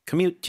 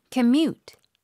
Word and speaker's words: commute: Commute 뒤에 강세요. 발음이요? – Commute. – Commute. – Commute. – 뒤쪽에 힘 잘 주시고.